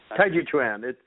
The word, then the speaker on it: Tai Chi Chuan: And I just reverted right back to your other book, your first book here, Tai Chi Quan. How do you pronounce that? – Tai Chi Chuan.